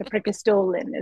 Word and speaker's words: Preikestolen: The Preikestolen is how it's pronounced.